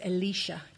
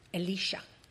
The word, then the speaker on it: Elisha: Okay, I have to pronounce it Elisha because I get him and the other one mixed up. Elisha.